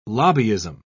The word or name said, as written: lobbyism